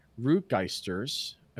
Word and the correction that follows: Regusters: It's Regusters.